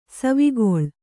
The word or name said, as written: ಸವಿಗೋಲ